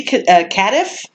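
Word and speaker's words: Cadiff: It was directed by Andy Cardiff, I think is how you say it. Mm-hmm. It's like Cardiff without the R.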